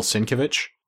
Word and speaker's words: Sienkiewicz: Sienkiewicz, which I hope I pronounced somewhat remotely correctly. – That's the way I've always heard his name pronounced, so.